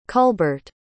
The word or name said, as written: culbart